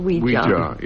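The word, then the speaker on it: Ouija: I think of an Indian mystic called Weejar. – Yeah. – I gave it the alternative pronunciation because it really does rather help. – Oh, yes, yes. It's known as a yes, yes.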